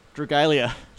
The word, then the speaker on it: Dragalia: How do you say this word? – Dragalia.